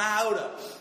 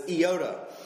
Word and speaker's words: iota: That's actually incorrect because when you read Greek, they don't have a long I sound made by the letter I. And that's what an iota is. It's the Greek letter I, but they don't pronounce it I. It's usually a short I, so it's iota, the smallest letter of the Greek alphabet.